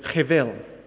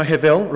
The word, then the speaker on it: hevel: Now the Hebrew word, I'll have to say it properly in case there's some Hebrew people here, but it's hevel, right? It's a guttural kind of sound, and since half of you blokes are Dutch, you're used to the sounding thing, so I've got to say it like that.